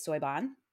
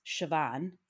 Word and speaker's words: Siobhan: Her middle name, I said, was Soiban. Mm-mm. It's actually pronounced Siobhan. It's an Irish name and it's spelled S-I-O-B-H-A-N. – Mm-hmm. – Soiban. Nope. It's Siobhan.